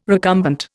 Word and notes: recumbent: Indian

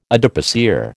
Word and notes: adipocere: American English

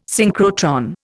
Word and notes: synchrotron: Indian

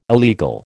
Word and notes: illegal: American English

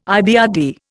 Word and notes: IBRD: Indian

